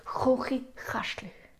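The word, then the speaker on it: Chuchichäschtli: So actually, it is they're almost impossible for non-Swiss people to pronounce. It is the hardest word, I think, one of the hardest words in Switzerland. But for us, for the Swiss German, it's like Chuchi Chäschli.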